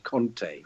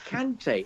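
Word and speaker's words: Kanté: I thought Kante was wonderful. Who I just despair of the television constantly calling him Conte. For God's sake, he's not Conte, he's the manager. He's Kante.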